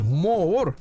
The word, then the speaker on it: Моор: Ну и сегодня в 1883 Дмитрий Стахиевич Моор родился. Две О, надо ага произносить четко достаточно.